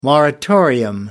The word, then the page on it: moratorium: American English